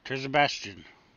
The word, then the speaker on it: Terzibashjian: How's it spelled? – T-E-R-Z-I-B-A-S-H-J-I-A-N. – Terzabastian. – Yeah.